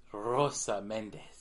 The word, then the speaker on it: Rosa Mendez: Dan, it's Rosa Mendez. You gotta pronounce it right.